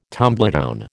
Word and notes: tumbledown: American English

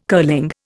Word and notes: curling: Indian

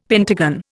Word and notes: pentagon: Indian

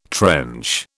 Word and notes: trench: British English

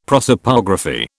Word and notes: prosopography: British English

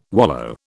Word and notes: wallow: British English

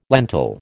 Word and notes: lentil: American English

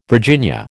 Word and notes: Virginia: American English

